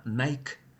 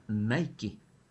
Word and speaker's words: Nike: Escuchamos anuncios en los que a tenis de moda se los llama Nike, en analogía a la forma de Mike, que se pronuncia sin la E, ignorando sin embargo por completo la pronunciación original Nike, o su hermoso equivalente en español Nike, la diosa griega de la victoria.